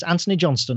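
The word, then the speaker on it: Antony Johnston: So my name is spelled, it's Anthony Johnston, and my name is spelled AntonyJohnston.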